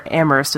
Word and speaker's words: Amherst: And I used to live in a town was named after this guy, Amherst, Massachusetts, or Amherst, as the locals say.